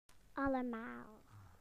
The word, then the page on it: everybody: British English